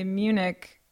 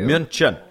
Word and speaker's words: München: I always just want to say Munich. – Right. – But that's not how... It's said or spelled. – No, yeah, that's not how that's spelled at all. – München. It's a hard U. München.